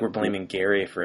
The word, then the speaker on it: Gerry: Elbridge Gerry was his last name. He pronounced it with a hard G sound. So we've ruined it by calling it Jerry.